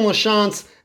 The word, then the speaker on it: LaChance: My personals, Brandon LaChance, the last name is spelled L A Chance with a capital C.